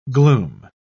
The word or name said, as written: gloom